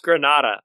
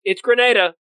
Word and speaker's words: Grenada: Me and Iggy today had a big argument about there was a hurricane that went through Grenada And Iggy was like, it's pronounced Granada. I'm like, no, it's Grenada.